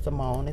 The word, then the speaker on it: Samone: All of, you know, Twitter, what's the other, Instagram, and all of the social media platforms, it's the same name, Selena Samone. It's spelled S-A-L-I-N-A-S-A-M-O-N-E.